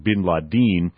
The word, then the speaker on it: bin Laden: I pronounce it bin Laden.